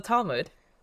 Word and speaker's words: Talmud: So the Talmud, I'm pretty sure that's how you say it. Yeah, it is.